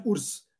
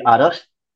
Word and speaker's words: Urs: Correct, uh, No, uh, that's a common uh, problem that every North Indian would have, simply because they would go by the anglicized spelling for it, where you call it URS, where you spell it URS but it is actually yes. actually Aras.